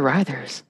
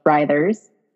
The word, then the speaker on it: Writhers: You could probably find everything on their Instagram page, the Riders, WRITHERS. Not the Riders. It's the Riders.